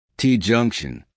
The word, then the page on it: t junction: American English